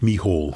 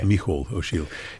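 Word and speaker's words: Mícheál Ó Siadhail: I think he pronounces it Michal. – Okay. – Michal O'Shiel.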